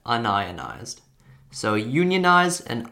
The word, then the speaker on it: unionized: And there's actually two ways that you can pronounce this word. You can pronounce this word as unionized, or you can pronounce it as un-ionized.